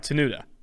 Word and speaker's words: Tenuta: Now joining the Tailgate Podcast is Virginia Tech offensive lineman Luke Tenuta. Do I have that pronounced right? Tenuta. – You do.